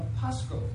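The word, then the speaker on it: pascho (πάσχω): The word there is pascho. Pascho. Phonetically, I spelled it that way. P-A-S-C-H-O.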